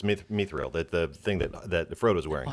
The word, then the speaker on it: Mithril: Mithril. M Y T H R I L. – Yeah, she's saying it right.